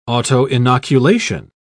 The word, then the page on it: autoinoculation: British English